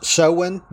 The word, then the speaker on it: Samhain: Now, if you look that word up, it's spelled S-A-M-H-A-N, but it's pronounced Samhain because languages are strange.